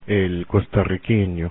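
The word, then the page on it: costarriqueño: Spanish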